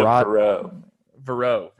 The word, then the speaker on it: Verrett: Is that how you say it? – Bishop Verrett. – Verrett. – Yes. It's a Catholic school, and it's, I would assume that's French, but yeah, Bishop Verrett.